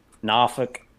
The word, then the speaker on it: Norfolk: Norfolk. I got to say that right now that I'm in Virginia. So well done. – Yeah, thank you. – You did great.